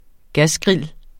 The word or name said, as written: gasgrill